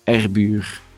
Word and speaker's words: Erbuer: My name is Moonen Erbuer, E-R-B-U-E-R.